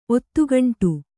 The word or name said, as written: ಒತ್ತುಗುಂಡಿ